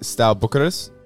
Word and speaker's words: Steaua Bucharest: Is it pronounced Steaua Bucharest? – Yeah.